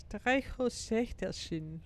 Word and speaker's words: Streichholzschächtelchen: Is a matchbox, and then the diminutive of it is a Streichholzschächtelchen. – Streichholzschächtelchen. – You're pretty good. I mean, your pronunciation is very good.